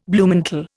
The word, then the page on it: Bluemantle: Indian